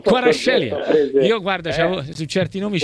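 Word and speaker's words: Kvaratskhelia: Quarascelia.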